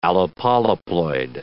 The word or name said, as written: allopolyploid